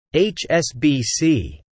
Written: HSBC

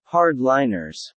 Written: hard-liners

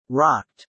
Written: rocked

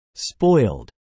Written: spoiled

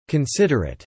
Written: considerate

